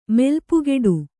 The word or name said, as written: ಮೆಲ್ಪುಗೆಡು